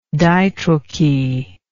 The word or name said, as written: ditrochee